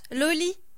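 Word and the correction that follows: Lolë: Lolé. – Lolé et je vais y arriver, hein. L-O-L-E Tréma, vous l'avez forcément vu quelque part.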